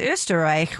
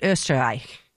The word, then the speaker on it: Österreich: And in Austrian, pronounced Österreich, which I did hear someone at some point say, no, that's ostrich.